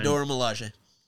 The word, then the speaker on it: Dora Milaje: The Dora Milaje. How do you say it? Sorry. – Yeah, Dora Milaje.